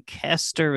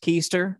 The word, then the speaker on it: Koester: For the sake of people who are just listening and not watching, Kester is spelled K-O-E-S-T-E-R, not pronounced Keister, right? That is something else. Craig Kester, K-O-E-S-T-E-R.